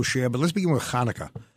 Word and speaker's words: Hanukkah: Rabbi, firstly, give us a brief reference of what Hanukkah is and what it is this year. – Sure. So Hanukkah, the Festival of Lights, those who don't have the C-H pronounced it Hanukkah, that's okay too, is a holiday on the Jewish calendar that emerged really about 2,300 years ago.